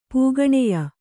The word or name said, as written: ಪೂಗಣೆಯ